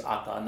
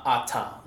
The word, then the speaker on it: Ata: So my name is Ata, not Ata, like if for Samoan.